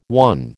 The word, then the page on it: won: American English